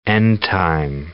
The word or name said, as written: end-time